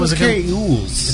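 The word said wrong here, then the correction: Elwes: It's Elves. That's how it's pronounced.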